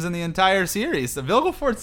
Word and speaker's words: Vilgefortz: I always pronounce his name Roggeveen. It's like her lover. – Oh! – How do I pronounce his name? – Vilgefortz. Vilgefortz, man.